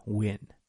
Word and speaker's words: Nguyen: However, Win here is spelled like the Vietnamese name Win, N-G-U-Y-E-N. So it's Win Win Lose Lose from Tao.